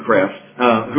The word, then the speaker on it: Kreeft: There was a philosopher and theologian named Peter Kreeft, some pronounce it Kreft, who writes about a fictional conversation between Socrates and a modern-day college student.